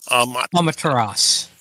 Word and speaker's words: Amaterasu: Amaterasu.